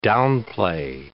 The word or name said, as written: downplay